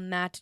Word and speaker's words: MANDT: It's called MANDT training, but it's spelled M-A-N-D-T.